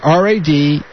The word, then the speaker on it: Radnofsky: It's Radnofsky. – Exactly. Three syllables, R-A-D, like Democrat, N-O-F, like Frank, S like Sam, K-Y.